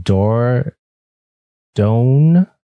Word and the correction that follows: Dordogne: okay, that's how I'll say that. – Dordogne. – Okay, appreciate that. – Yeah, it's French, and I'm still trying to figure out the correct way to say it.